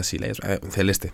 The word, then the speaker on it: Celeste: Yo digo Celeste también, aunque entiendo que la pronunciación original y correcta sea Celeste, no pasa nada. Se puede españolizar si nos resulta cómodo, entendemos todos qué juego es, no hay ningún problema.